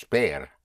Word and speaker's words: ver: So to see is bear, yes, like seeing the bear.